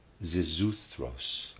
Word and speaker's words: Xisuthros: Xisuthros, I'll try to pronounce it, in the epic, spelled X-I-S-U-T-H-R-O-S.